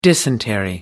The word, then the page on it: dysentery: American English